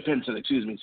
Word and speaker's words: Pinson: And then they also didn't re-sign or let the option go on Theo Pinion. I think I mispronounced his name, as well as Kenny Wooten. – Yeah, Theo Pinson.